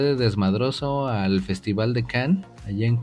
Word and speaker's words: Cannes: De hecho, se pronuncia Cannes.